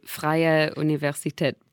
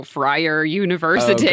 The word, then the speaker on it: Freie Universität: Because I can't just go Freier University.